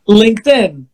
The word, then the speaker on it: LinkedIn: ¿Cómo se pronuncia? LinkedIn. – LinkedIn, ok o que eh, nosotros conocemos como LinkedIn, pero bueno, así se pronuncia, no como lo dije yo.